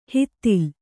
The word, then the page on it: ಹಿತ್ತಿಲ್: hittil